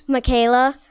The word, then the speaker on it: Mikayla: Michaela. – Michaela, that is a very beautiful name. How do you spell it? – M-I-K-A-Y-L-A.